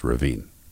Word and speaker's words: Rovinj: And, the architecture, say you pick Rovinj, Croatia, which is spelled R-A-V-I-N-G-E, but it's pronounced Rovinj. It's probably pronounced something else if you're Croatian, but that's how I was taught to pronounce it.